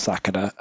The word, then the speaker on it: Sakura: I can definitely say out of all of the people there, Sakura is one of the most common female names in Japan, and that is how it's always pronounced. As a lifelong, and by lifelong I mean a slight Naruto fan, Sakura is pronounced Sakura.